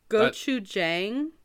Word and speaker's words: Gochujang: Gochujang? – Yeah.